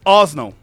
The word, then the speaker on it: Arsenal: Pero en Inglaterra son dos sílabas. – A ver. – Uy, estoy quedando un pedante Orson. Horrible. – Orson. – Es Osno.